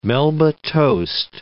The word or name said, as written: melba toast